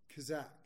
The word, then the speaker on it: chazaq: And this word in Hebrew, forgive my Hebrew pronunciation because it's not very good, is C-H-A-Z-A-Q, Kazak.